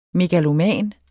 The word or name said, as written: megaloman